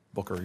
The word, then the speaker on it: Booker: Booker, you pronounce it.